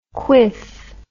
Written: kweef

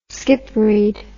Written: skipread

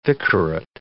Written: vicarate

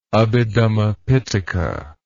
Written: Abhidhamma Pitaka

